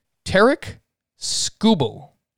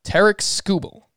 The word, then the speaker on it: Tarik Skubal: And I actually was watching the Detroit broadcast, and they pronounce his name Tarik Skubal. Tarik Skubal.